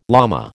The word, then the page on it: llama: American English